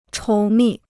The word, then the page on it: 稠密: chóu mì